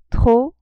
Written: trop

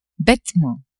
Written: betement

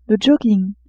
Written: jogging

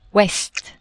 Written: ouest

